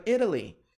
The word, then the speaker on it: Italy: Why can't we go to Italy? Pronúncia, Italy, Italy, Italy, Italy, Italy. Okay? Mas americanos pronunciam esse T com som do R brasileiro, né?